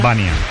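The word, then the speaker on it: Vanja: Sí, es esloveno, es Vania, por la Jota como el indiano. – Vania.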